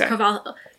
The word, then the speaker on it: Carvalho: Is there an L in there? – Yeah, there is an L in there. Okay. Ali'i, I think, is how you pronounce her first name. – Yeah, her last name. Isn't there... Is it there an L in her last name? – Yeah, there is. Carvalho. Yeah, I think it's Portuguese.